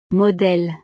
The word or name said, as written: modele